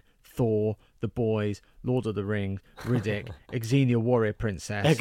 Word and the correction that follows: Xena: How do you say it? – Xena. – Well, it's Xena, like Xavier Dolan.